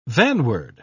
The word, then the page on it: vanward: British English